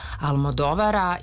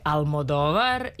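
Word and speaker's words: Almodóvar: To je novi film Pedra Almodovara i ja ću ponovo akcentovati tako, jer se kaže Una pelikula de Almodovar, iako smo mi navikli još od 90-ih, kada smo se ranih 90-ih upoznali sa njegovim radom i sa visokim potpeticama, se uvrežilo Almodovar.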